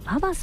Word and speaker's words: VAVA: そして南青山にフレンチレストランシルクさん、 CILQ と書いてシルクさん、そして霞ヶ関にエビ専門店シュリンプガーデンさん、そして日本橋高島屋さん新館にございますカフェババさん、 VAVA と書いてね、カフェババさんとございまして、この中からですね、今月は銀座にございますシーフードレストラン火山さんのペアお食事券 8000 分となっております。